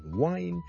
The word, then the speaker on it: whine: 其實如果你學某一些 accent 是的確是會讀 wine 這樣的，例如愛爾蘭的英文很多時候都會聽到 wine W-I-N-E 和 W-H-I-N-E 發音是不同的，聽著。第一個是 wine wine wine wine wine，第二個是 wine wine wine wine wine，聽下去你的感覺去當它好像 H 音之前跟著先加個 wine， wine and wine，那就不是錯不錯的問題，而是不同地方口音。